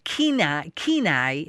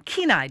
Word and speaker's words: Kenai: Nicole has posted this on Facebook, and we have multiple callers who are asking the same question that Leanne from Kenai, Alaska, is going to ask. And I probably said that wrong. Kenai, did I say that right, Leanne? – Yes, you did.